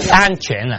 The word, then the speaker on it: 鹌鹑: 哎，问你，顺便，以色列人在旷野吃玛拿之外，还吃一种鸟呢，是怎么念？哈？鹌鹑呢？鹌鹑鸟。 OK，鹌鹑。